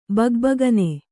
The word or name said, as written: ಭಗಭಗನೆ